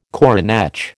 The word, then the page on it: coronach: American English